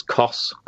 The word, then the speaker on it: Koss: Koss. Is that how you pronounce it? Koss. – I think so, yeah. I think it's Koss Corp.